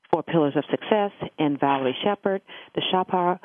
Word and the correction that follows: Sherpa: Shapar, I'm saying that wrong, aren't I? The Sherpa, Sherpa, Sherpa.